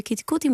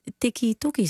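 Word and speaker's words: Keti Koti: Maar de gemiddelde Nederlander, ja, we hebben net van onze eigen, ik claim hem niet, maar de beoogde premier van Nederland, die niet eens weet hoe je kitty-kotty moet uitspreken, die tikkie-tokkie zegt of zoiets.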